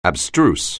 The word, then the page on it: abstruse: anglais